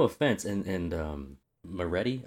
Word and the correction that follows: Moretto: Oh, am I getting the name wrong? Moretto. Not Moretti. Sorry.